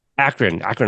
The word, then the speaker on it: Akron: Is it Akron, Ohio? Is that Akron. How you say it? Akron, Ohio. See, I knew it didn't Akron. Sound right.